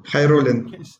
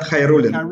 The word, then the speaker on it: Khairullin: Khairullin. It's Renat Khairullin. – Khairullin. – Khairullin.